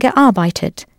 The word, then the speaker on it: gearbeitet: Ja, oder dort habe ich 2 Jahre lang gearbeitet. That's G-E. A-R-B-E-I-T, E-T, gearbeitet. Note that, for ease of pronunciation, you had to add an E-T to Arbeit, not just the normal T.